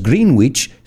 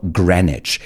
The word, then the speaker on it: Greenwich: No decimos Greenwich, sino Greenwich.